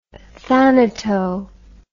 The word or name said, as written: thanato